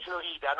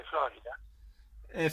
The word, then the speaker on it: Florida: Florida, non si dice Florida. – Florida lo dico in messicano.